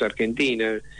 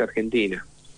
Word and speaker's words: Argentina: El argentín, no, la traducen directamente, lamentablemente tuve que insistir en que se tenía que pronunciar como lo pronunciamos nosotros, Argentina, y no como se pronuncia en inglés, Argentina.